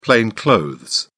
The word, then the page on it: plain clothes: British English